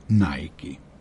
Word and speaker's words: Nike: Tačan odgovor je kao Palmolive Garnier i Nike.